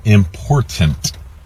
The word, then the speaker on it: important: The problem is you're mispronouncing it. It is important. Like my girlfriend and wife say, mind the T's.